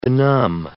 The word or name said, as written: benumb